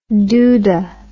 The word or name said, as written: dooda